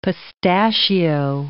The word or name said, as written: pistachio